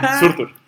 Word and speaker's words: Surtur: Además, así se pronuncia. – Surtur.